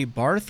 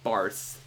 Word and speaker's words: Barth: But I do know Karl Barth is, a lot of people, they see the H at the end of the name and they think it's Barth, but it's actually, the H is silent.